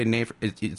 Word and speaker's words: NAFRS: And so the board approved the full-time chief budget, the Joint Powers Board for NAFRS, N-A-F-R-S. I heard that this morning, too. Because I always say NAFRS, spell it, yes, N-A-F-R-S, NAFRS. – It sounds like wafers It does.